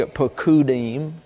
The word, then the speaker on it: pekudim: Let's take a look at pekudim. It's pronounced that way, pekudim.